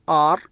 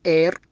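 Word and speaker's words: are: الكلمة الثانية حالة تعتبر شاذة، تنطق آر ولا تنطق إير، مع أن المفروض أنها تنطق إير لأن في حرف إي، لكن هنا تنطق بحرف الآر.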